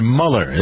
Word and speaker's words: Mueller: The FBI has sent the director, Mr. Mueller, that's how you pronounce his name, M-U-E-L-L-E-R, right? Mueller, has gone over to meet with Putin.